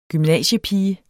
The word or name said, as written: gymnasiepige